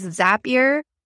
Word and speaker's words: Zapier: And yes, it is Zapier, rhymes with happier.